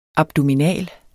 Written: abdominal

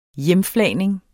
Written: hjemflagning

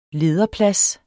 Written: lederplads

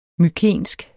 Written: mykensk

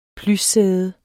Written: plyssæde

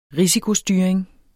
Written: risikostyring

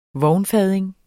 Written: vognfading

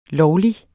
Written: lovlig

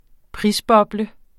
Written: prisboble

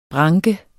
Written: branke

